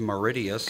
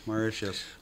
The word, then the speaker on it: Mauritius: I didn't know it was pronounced Mauritius. – Good for them. – And then what's number Mauritius. – One? – Mauritius. – That's how they say it, Mauritius? – Mauritius.